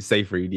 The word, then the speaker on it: Seyfried: But Rachel actually auditioned for the role of Sophie for Mamma Mia, but lost out to Amanda, how do you say it, Seyfried? – Seyfried, yeah.